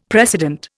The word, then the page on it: precedent: Indian